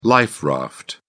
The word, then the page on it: life raft: British English